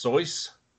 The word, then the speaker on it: Seuss: Did you guys know that Dr. Seuss is actually supposed to be pronounced Soyce? – That doesn't sound good, though.